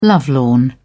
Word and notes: lovelorn: British English

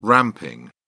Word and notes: ramping: British English

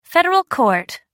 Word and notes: federal court: American English

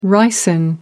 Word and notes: ricin: American English

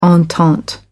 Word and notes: entente cordiale: American English

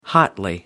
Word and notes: hotly: American English

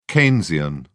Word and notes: keynesian: British English

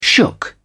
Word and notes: shook: British English

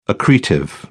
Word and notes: accretive: British English

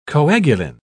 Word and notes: coagulin: British English

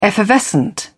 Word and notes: effervescent: British English

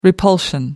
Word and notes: repulsion: American English

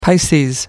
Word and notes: pisces: American English

